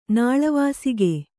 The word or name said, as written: ನಾಳವಾಸಿಗೆ